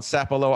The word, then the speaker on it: Sapelo: But can you please explain to some of the people that don't, growing up on Sapelo Island, if I even said that right? – Yeah, you said it right. Most people pronounce it wrong, but you're pretty close to dead on.